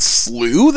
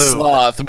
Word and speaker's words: Slough: Is it a slough? Slough? What is the official pronunciation of this? – I think it's slew, they said. – Slew? Man. – The trick tweeted about it, because I know I called it sloth, but I believe it is slew, yeah.